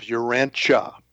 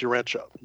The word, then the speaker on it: Urantia: We use the pronunciation of Urantia. So it's Urantia, Urantia book.